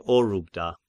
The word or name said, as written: Orubda